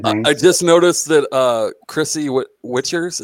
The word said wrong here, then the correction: Wickers: is that how you say it, Witchers? – Wickers? – Wickers? Look, those of you that know her, because I've never actually met her in real life. How do you say her name? – Wickers, like the basket.